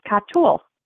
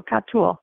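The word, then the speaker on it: katul (חתול): But in the Hebrew, it is pronounced katul. That's how you say cat in Hebrew, katul.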